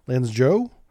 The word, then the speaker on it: Lanzhou: This is the Lanzhou, I believe is the proper pronunciation there.